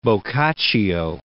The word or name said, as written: Boccaccio